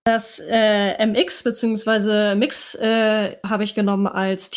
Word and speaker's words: Mx: Also statt Mr. oder Mrs. sagt man halt Mix, wird also ausgesprochen und wird halt MX geschrieben.